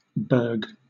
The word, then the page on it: berg: British English